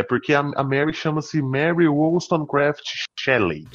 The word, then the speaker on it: Wollstonecraft: Mary Wollstonecraft. Você tem que estar tipo eu, assim, depois de duas garrafas de cerveja. – Mary Wollstonecraft.